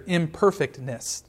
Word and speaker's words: imperfectness: The continual, yearly sacrifice was a constant reminder of their imperfectness. I emphasized the wrong syllable there. It was a reminder of their imperfectness. There we go.